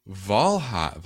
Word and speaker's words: Valhi: This is Valhi, Valhi, Valhi, V A L HI that's how you spell it.